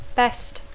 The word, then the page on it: best: English